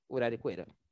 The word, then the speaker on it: Uraricoeira: As margens do rio mítico Rio Uraricoeira. Falei certo? Uraricoeira. – Acho é, Ela deve estar tá certa, bicho.